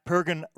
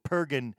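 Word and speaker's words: Purgen: At Pergin Punks on Facebook and at official underscore Pergin on Instagram, and in case I'm mispronouncing, it's P-U-R-G-E-N, Pergin, or Pergin.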